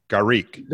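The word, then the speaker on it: garrigue: Garrique. – Don't ask me how to spell it, but it's garrique. – Garrique. – Not okay, garlic, no, that's, but garrique.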